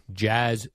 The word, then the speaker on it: Jazz: Add him to the long list, Jerry, of people who can pronounce Jazz. – Chaz Chisholm. – No, Jazz Chisholm.